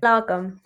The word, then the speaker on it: Lagom: And I know that your podcast, A Little Bit of Lagom, is that how to pronounce it? – Lagom.